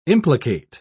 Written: implicate

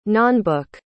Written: nonbook

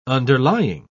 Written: underlying

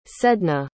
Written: sedna

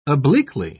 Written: obliquely

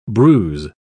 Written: bruise

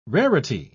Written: rarity

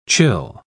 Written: chill

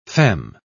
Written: femme

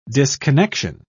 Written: disconnection

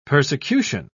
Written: persecution

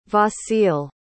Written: vasyl

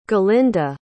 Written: galinda

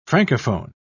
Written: francophone

